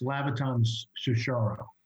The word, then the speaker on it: Labaton Sucharow: Labaton Sucharow. Am I saying that right? – It's Labaton Sucharow.